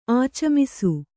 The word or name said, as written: ಆಚಮಿಸು